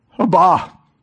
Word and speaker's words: Abba: And you and I here today can be forgiven because Jesus said, "Dad, Abba, Abba, Abba," is how it's pronounced, but he says, "Abba, Father, forgive them.